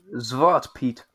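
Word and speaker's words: Zwarte Piet: It's pronounced as Zwart Pete.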